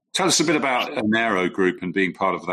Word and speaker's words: Enero: Am I pronouncing that right? Enero? – Yes.